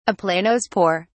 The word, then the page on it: aplanospore: British English